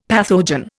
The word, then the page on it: pathogen: Indian